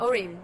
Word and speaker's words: Orin: Orin, beautiful.